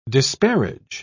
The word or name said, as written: disparage